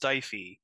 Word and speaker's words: Stifey: it says in the book that the name is pronounced Stifey, but it's the guy's name is Michael Stifey, but it's spelled kind of like Stiffy, which is definitely a good pun thrown into the book.